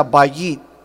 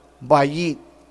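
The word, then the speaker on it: bayit (בַּיִת): Si Jehová no edifica la casa, la palabra casa viene una palabra hebrea que se pronuncia bayit, bayit, que significa, mis hermanos, escúchame, familia.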